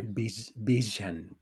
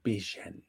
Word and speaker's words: Bijan: Bijan, Bijan Robinson. Porque ya ves que es como, lo pronuncia como con ZH, ¿no? – Sí.